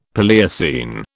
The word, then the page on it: Paleocene: British English